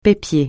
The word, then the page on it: pépier: francais